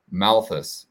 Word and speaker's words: Malthus: What I've always believed that the World Economic Forum, they're for the most part Malthusian, and Thomas, I think his name is Malthus, is the way you pronounce it correctly.